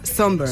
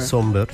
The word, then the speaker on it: Sombr: Pues mira, yo busqué cómo se pronunciaba por si acaso, pero efectivamente se pronuncia Somber. – Somber, sí. – Y se escribe Somber, Agus. Somber. – Sí, sí, sí. – Exacto. – Y se escribe S-O-M-B-R, por si la gente lo quiere buscar.